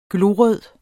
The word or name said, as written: glorød